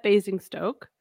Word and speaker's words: Basingstoke: Okay, so the Fries will be at Basingstoke. Mm-hmm. Did I say that right? – Yes. Yep, you did.